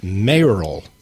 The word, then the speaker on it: mayoral: And so it's mayoral. – Mayoral. – Because you're running for mayor.